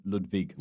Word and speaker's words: Ludwig: I've listened to Ludwig Kaiser, who nobody in Germany would ever spell with a V. Yeah. Oh, it is a W then, yeah. Ludwig. But it is pronounced Ludwig.